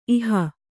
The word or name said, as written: ಈಹೆ